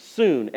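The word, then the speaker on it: soon (σύν): It's S-U-N. It's like it's spelled like the sun, but it's pronounced soon, S-O-O-N.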